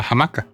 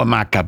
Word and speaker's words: hamaca: Y el mejor, Día de la Jamaca. – ¿Día de la hamaca? – De la jamaca. – Bueno, jamaca le decís tú, pero yo le digo hamaca porque la H no se pronuncia.